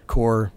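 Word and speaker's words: Corps: Core, silent S there at the end, right.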